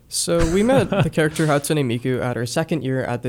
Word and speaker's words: Hatsune Miku: Do you even want to say his name for me? Hatsune Miku. Miku. – Hatsune Miku. Yeah, that yes. – was really close. – That was closer than most.